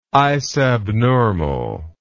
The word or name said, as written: isabnormal